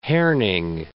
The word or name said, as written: Herning